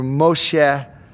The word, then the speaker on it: Moshe: Moses is in Hebrew Moshe.